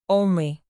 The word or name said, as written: only